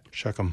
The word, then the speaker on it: Shechem: How do you say that? – Shechem. – Shechem, yeah. From the sons of Hamor, who is the father of Shechem.